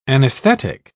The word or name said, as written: anesthetic